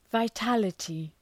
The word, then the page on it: vitality: vaı'tælətı